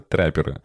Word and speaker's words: траппер: Трапперы и ударение. Это трэперы Ладно, не буду переговаривать.